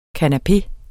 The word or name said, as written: kanapé